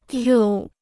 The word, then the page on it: 皱: zhòu